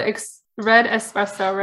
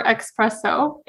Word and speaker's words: espresso: We'll have to have our little mugs and drink this concentrated red espresso, right? – Yes. – Oh, by the way, how do you say it? Do you say espresso or expresso? – Espresso.